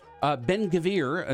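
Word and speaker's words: Ben-Gvir: Ben-Gavir, is that how you pronounce his name? – You're right.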